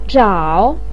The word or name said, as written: zhao3